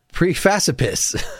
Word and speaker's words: Precipice: So I will play a little bit of Every Time by Flat Earth from Precipice from 1991. Is that the correct pronunciation of the made-up word? – I don't really know that there should be one, but I would always say precipice.